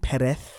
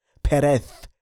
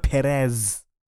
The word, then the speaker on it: Perez: Yes, that's how you pronounce his surname, Perez. It is Spanish. It is not English. Nitanda gliti English. It is Perez. The Z is pronounced as th. Okay? Perez. Not Perez.